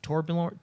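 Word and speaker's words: Torbjörn: And it's Torbjorn. How yeah. – you pronounce his name? – Yeah, yeah. – Those silent J's, man.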